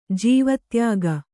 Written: ಜೀವತ್ಯಾಗ

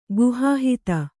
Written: ಗುಹಾಹಿತ

